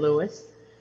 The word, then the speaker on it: Lewis: My full name is Sheila Annette Lewis, and my first name is spelled S-H-E-I-L-A, last name Lewis, L-E-W-I-S.